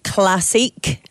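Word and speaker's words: Classique: So yeah, we had Ride London Classic, as it should be pronounced, because they go with a French spelling, bizarrely.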